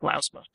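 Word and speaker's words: Lousma: Jack Lausma's last name is pronounced more like Lausma. – Lausma, not Lausma.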